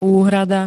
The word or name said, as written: úhrada